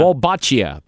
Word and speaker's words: Wolbachia: Wolbachia. – Don't know it. – Wolbachia.